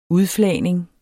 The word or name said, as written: udflagning